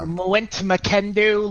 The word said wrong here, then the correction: Matim Mwekundo: Life Improved, or something like that. Yeah, it's Matim Mwekundo.